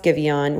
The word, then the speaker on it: Giveon: It's a little romantic, and it's by Giveon, which is spelled Give On. G I V E O N, but it's pronounced Givion, which I absolutely love his name.